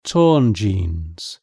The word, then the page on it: torn jeans: British English